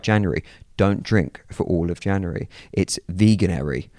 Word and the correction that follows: Veganuary: Is that, am I saying that right? Veganary? – I thought it was veganuary. But veganuary. however you want to... Well, that's part of the problem with their branding. No one quite knows how to say it.